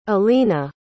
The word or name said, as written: elina